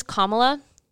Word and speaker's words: Kamala: But her name is Kamala. Pronounce it as such.